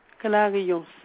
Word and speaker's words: Clarion: Clarion, C-L-A-R-I-O-N. – Mm-hmm. Mirette, et votre prénom? – Mirette, comme ça se prononce, M-I-R-E-D-T-E.